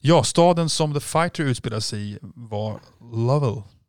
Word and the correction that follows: Lowell: Lowell. Lowell? Tror man Lowell, ja.